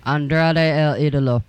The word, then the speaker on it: Andrade El Idolo: Andrade El Idolo.